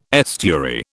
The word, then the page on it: estuary: British English